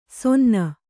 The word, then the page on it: ಸೊನ್ನ: sonna; Kannada